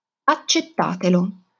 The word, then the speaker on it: accettatelo: Attenzione all'accento della pronuncia. È una seconda persona plurale. Accettare, quindi l'accento cade sulla A accettate, accettatelo.